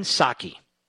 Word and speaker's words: Psaki: Jen Psaki, where the P is silent, of course.